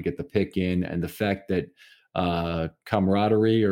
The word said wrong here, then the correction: Camara: or whatever his name is, Camara, that's how I pronounce it.